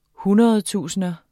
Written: hundredtusinder

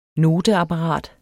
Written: noteapparat

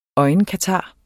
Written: foder

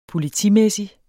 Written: politimæssig

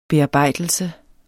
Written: bearbejdelse

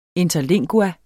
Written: interlingua

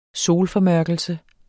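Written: SOLFORMØRKELSE

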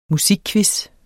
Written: musikquizzer

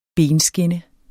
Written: benskinne

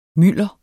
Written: mylder

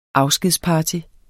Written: afskedsparty

